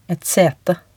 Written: et sete

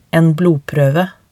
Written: en blodproeve

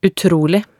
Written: utrolig

